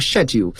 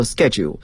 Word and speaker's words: schedule: 英国人呢往往是把它读作 schedule，可是美国英语呢，它这种发音模式和古英语是不一样的，要读作 schedule，表示工作安排呀，日程安排。